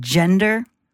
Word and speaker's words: gender: If you are going to use the word gender, it's a soft G at the beginning there. Gender. It's not a hard G, okay?